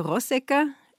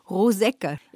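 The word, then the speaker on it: Rosegger: Übrigens könnte man kurz erwähnen, hier bei uns sagt man Peter Rossecker, da wo er zu Hause ist, in der Steiermark, sagt man Rossecker. – Ja, es gibt diese beiden Aussprachen.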